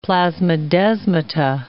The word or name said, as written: plasmodesmata